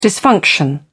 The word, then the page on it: dysfunction: British English